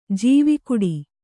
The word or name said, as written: ಜವುಗಿಡು